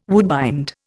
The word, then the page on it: woodbind: Indian